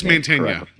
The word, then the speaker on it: Mantegna: And Joe Magten, I always forget how to pronounce it. It's Mantegna. – Mantegna, okay. Yeah.